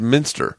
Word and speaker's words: Minster: I've been saying Matt Munster, who on DIY Networks, and it's Minster. This whole time I've been calling him Matt Munster, like the cheese.